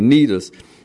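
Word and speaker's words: Cnidus: Sinidas, chapter, I mean, verse 7. – The sea is silent. I think it's Nidus. The sea is silent. Okay, Nidus.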